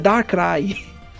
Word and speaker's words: Darkrai: É Darkrai.